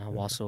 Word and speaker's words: Owasso: Yeah, Owosso, Oklahoma.